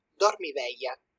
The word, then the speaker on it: Dormiveglia: okay, so it's just in Italian. It's like dormiveglia. – Dormiveglia because oh, the G L and I is like ye. – Mm. Okay. Okay, dormiveglia.